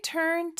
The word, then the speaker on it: turn: 근데 turn이라는 단어가 R 발음이 조금 어려워요. 우리는 이 단어를 turn 이렇게 발음하는데 으에 더 가깝고요. 모음 소리는 turn 이렇게 R 발음 나면서 은으로 끊어주시면 돼요.